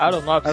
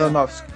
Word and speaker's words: Aronofsky: Darren Aronofsky. Aronofsky. Né? Aronofsky. Tem um F no meio do nome dele, mas ele é muito bem retratado o vício, porque ele retrata visualmente e auditivamente muito bem.